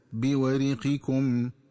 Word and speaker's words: بورقكم: فعند قراءة الآية 19 وهي قوله تعالى: فبعثوا أحدكم بورقكم هذه إلى المدينة فلينظر أيها أزكى طعاما فليأتكم برزق منه وليتلطف ولا يشعرن بكم أحدا. – ينبغي التنبه للنطق الصحيح للفظ بورقكم، فيقرأ بكسر الراء هكذا بورقكم، والورق هو الفضة.